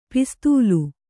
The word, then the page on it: ಪಿಸ್ತೂಲು: Kannada